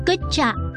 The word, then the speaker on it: Good job: 英语的辛苦我们可以说 Good job，读起来亲像骨架 Good job。